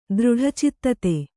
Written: ದೃಢಚಿತ್ತತೆ

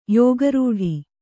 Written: ಯೋಗರೂಢ

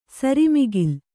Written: ಸರಿಮಿಗಿಲ್

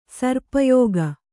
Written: ಸರ್ಪಯೋಗ

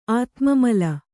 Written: ಆತ್ಮಮಲ

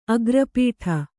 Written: ಅಗ್ರಪೀಠ

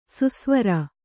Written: ಸುಸ್ವರ

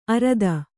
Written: ಅರದ

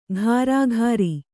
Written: ಕರಕರಿ

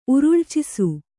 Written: ಉರುಳ್ಚಿಸು

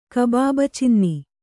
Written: ಕಬಾಬಚಿನ್ನಿ

